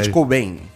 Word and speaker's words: Kurt Cobain: Em 1967, nasce o cantor e compositor norte-americano Kurt Cobain. Mas pronunciei isso, é isso? – Kurt Cobain.